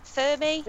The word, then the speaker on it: Fermi: What about the Fermi paradox? – Yeah. – Am I saying that right? Fermi.